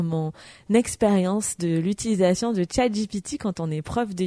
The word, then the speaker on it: ChatGPT: Et voilà, c'est déjà la fin de cet épisode de bilan qui d'ailleurs a été fait en co-création avec ChatGPT enfin ChatGPT, comme dirait ma maman qui m'a suggéré de le prononcer à l'anglaise parce que c'était quand même plus joli qu'à la française.